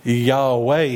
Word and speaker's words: Yahweh: So this would actually be pronounced Yahweh here.